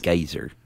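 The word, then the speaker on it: Geysir: Geyser.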